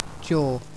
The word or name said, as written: jaw